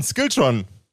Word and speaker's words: Skiltron: Is, it, uh, is did I pronounce that right? That's perfect. I uh, um, I thought it would have a more um, Scandinavian uh, ring to it when I say it, like Skilltron.